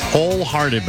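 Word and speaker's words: Hole Hearted: It's wholehearted, but it's spelled H-O-L-E, hearted.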